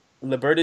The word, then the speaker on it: Liburd: Liburd is your real last name. Mm-hmm. How do you say that in Spanish? – Liburd. – Oh, okay. Silent D. – Yeah. – That's what's up. – Liburd. – It sounds like mad French. – Yeah. Well, it is French. Oh, shit. Sorry.